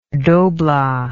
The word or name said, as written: dobla